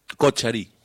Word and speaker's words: Kochari: Que nos dirá si se pronuncia Kochari, Kochari... – Kochari. – Kochari. – Kochari. – No era ni una ni otra. – Estaba muy bien igual, estaba cerca.